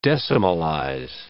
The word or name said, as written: decimalize